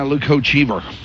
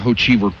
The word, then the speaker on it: Hochevar: Yeah, Luke Hochevar. – Is that how you pronounce it? – Yeah, Hochevar.